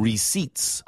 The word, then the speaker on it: receipt: I always double check ATM receipts. – R-E-C-E-I-P-T에서 P 발음이 묵음입니다.